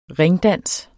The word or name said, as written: ringdans